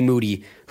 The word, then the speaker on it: Moody: Podcast co-host of the year, G. Moody, whose last name also rhymes with Duty.